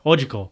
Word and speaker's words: Ochako: Uraka. I can never say her name. – Just say Ochako. – Ochako, right? – You said it wrong again. Ochako. – Oh, Ochako.